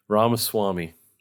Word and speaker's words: Ramaswamy: Ramaswamy.